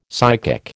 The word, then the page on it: psychic: American English